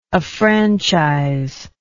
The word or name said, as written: affranchise